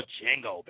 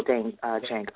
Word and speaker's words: Django: I do call you the metaphysical Django. I almost said the D is silent.